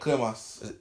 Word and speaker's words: Cremas: So is it cremas? Is that how you say it? – Cremas.